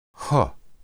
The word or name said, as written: heu